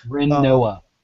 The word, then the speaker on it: Rinoa: I did manage to get Celeste, and I'm working on the Riona, or Rinoa. I've always thought it was Riona. I've never actually looked at the name carefully enough to realize I've been mispronouncing it this entire time. – Rinoa.